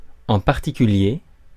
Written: rifle

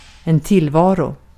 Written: tillvaro

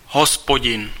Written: God

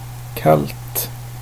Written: kallt

